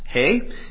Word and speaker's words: He: Psalm 119, and we are in the fifth section, which is titled Hey, or you might read it as He, but I think it's Hey is how you would pronounce it.